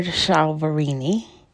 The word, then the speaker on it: Chiaverini: It's talking about New Year's, and it is a New Year's quilt by Jennifer Chiaverini. It's spelled C H I A V E R I N I.